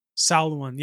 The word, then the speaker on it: Samhain: Yeah, in my research up front, I will say that I was going to say Samhain, but Samhain, or how did you pronounce it again? What was the original pronunciation? Samhain. Yeah, Samhain. I feel like I've heard that before.